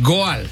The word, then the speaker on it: Goal: No, Gol. Ellos lo estaban pronunciando como Gol. O sea, suena así, pero en inglés se dice goal, es meta, ¿no? – Ajá, goal.